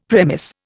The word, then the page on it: premiss: Indian